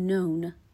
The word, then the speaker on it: known: به تلفظ فعل known دقت کنین این noun نیست known